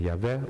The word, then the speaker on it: Yahweh: Toen de mens en zijn vrouw, en ik ga meteen zeggen, ik ga Gods naam als Jehovah uitspreken. Sommige mensen zeggen Yahweh of Jeweh, dat maakt mij allemaal verder niet uit.